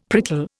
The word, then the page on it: prickle: Indian